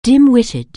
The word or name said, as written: dim-witted